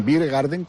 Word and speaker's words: beer garden: Con tardeo van a cerrar después de Madalena para prepararlo de cara a abrir un patio gastronómico, un beer garden que le llaman. – Big Garden. – Beer Garden.